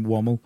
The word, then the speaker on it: Womwell: It's spelt Womwell, but we call him Wommel.